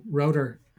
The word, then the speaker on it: Rotor: I keep saying Rotor. You keep saying router. It's Rotor videos. I'm assuming we're saying it differently because of our dialects, accents. – Probably my accent. Yeah, no, you're saying it right. It's like, you know, Rotor. It's like motor with an R.